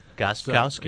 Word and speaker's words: Gostkowski: With Steven Gostkowski, Patriot place kicker, and I say your name, Gostkowski, because I was told last year before my first Patriots broadcast that either leave the T or the K silent, whichever you prefer.